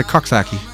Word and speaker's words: Coxsackie: Did they call it Coxsackie? – They call it a Coxsackie. – Oh yeah. – Yeah, that was the official NBC pronunciation.